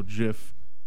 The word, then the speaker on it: GIF: So it should be JFEG, but it's not. It's JPEG, JPEG. So it's also GIF. That's how you say it.